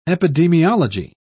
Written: epidemiology

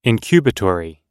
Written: incubatory

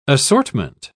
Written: assortment

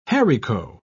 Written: haricot

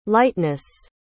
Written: liteness

